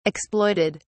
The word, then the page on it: exploited: British English